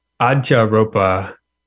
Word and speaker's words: Adhyâropa: Adhyâropa. So it's a Sanskrit word, like, Adhyâropa is probably how they say it.